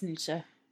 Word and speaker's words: Nietzsche: And he spends most of his time reading Nietzsche. Did I say that right? I always say it's Nietzsche.